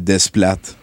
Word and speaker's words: Desplat: C'est Desplat.